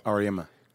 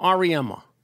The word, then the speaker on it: Auriemma: Gino, how do you say his last name? Because I want to be respectful. – Auriemma. – Gino Auriemma.